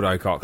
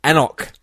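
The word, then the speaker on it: anCnoc: No, wait, it's not called Ocock. – Oh, wait, what is it? – According to the note on the bottle... – Excellent. – It is actually pronounced Anok.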